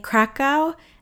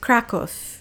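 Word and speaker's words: Kraków: A lot of people say Krakow, and it's sometimes spelled with a K or a C, but it's actually pronounced Krakow, at least if you ask the Poles.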